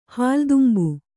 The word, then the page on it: ಹಾಲುತುಂಬು: hālu tumbu